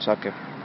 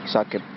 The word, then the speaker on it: Saqib: Badr bin Saqib. – Bin Saqib. – Saqib, how do you spell that? – S-A-Q-I-B.